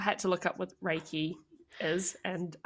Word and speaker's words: Reiki: So after the course, she was able to start her own business as, I'm going to say this wrong, as a Reiki practitioner. – A Reiki, yeah.